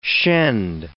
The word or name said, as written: shend